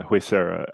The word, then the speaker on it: Huesera: So uh, basically, um, the, the, one of the biggest things that drew me to uh Huesera, I still don't know if I'm pronouncing it correctly, uh, but yes, I yeah.